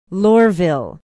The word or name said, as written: lohrville